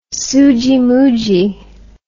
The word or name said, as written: soogiemoogie